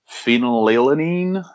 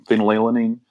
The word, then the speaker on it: phenylalanine: phenylalanine, I think is the way you pronounce the chemical name, phenylalanine.